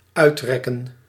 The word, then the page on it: remonter: France; [ʁǝ.mɔ̃.te]